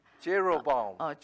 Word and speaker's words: Jeroboam: Jeroboam. – Jeroboam second.